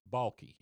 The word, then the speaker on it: Balke: And his name was Charles Balke, and I hope I'm pronouncing that right, B-A-L-K-E. – Okay. – Is that how you'd pronounce that? – Yeah, sounds good to me.